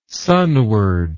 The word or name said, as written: sunward